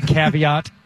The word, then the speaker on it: caveat: Can you just say caveat? – Caveat. – As opposed to the actual French pronunciation? – Caveat. – Because that throws people off.